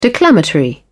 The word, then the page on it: declamatory: British English